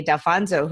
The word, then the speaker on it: Dalfonzo: Today we're talking to Stephanie Dalfonzo, who is an intuitive women's leadership coach. Did I pronounce the last name correctly? – You did. Spot on.